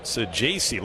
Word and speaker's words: Sejacy: And Thomas Sejacy looks at a called strike from Piamps. By the way, that is the way his last name is pronounced, we've come to learn.